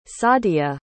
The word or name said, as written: saadya